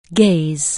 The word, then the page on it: gaze: anglais